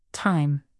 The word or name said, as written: time